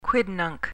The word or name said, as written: quidnunc